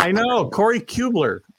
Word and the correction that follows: Kluber: I always put the L in the wrong place. – Kubler? – Kluber.